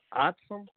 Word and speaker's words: OTSM: And in there, we have the Our Turn Social Media, OTSM, pronounced OTSM, like awesome, except for OTSM, OTSM chat room.